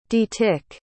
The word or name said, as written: detick